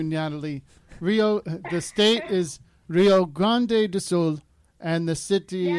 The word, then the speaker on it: Rio Grande do Sul: and the state is called Rio Grande do Sul.